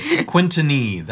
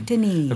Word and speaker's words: Quintinye: Quintinye. That's the proper French pronunciation. – Quintinye.